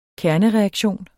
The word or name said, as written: kernereaktion